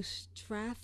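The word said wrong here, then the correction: Stratholme: I think it's pronounced Strathholm.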